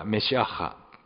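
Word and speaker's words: メ シア ッハ (מָשִׁיחַ): ヘブル語ではメシアッハというふうに発音しますけれども、ギリシャ語ではクリストスと言います。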